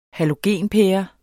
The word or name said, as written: halogenpære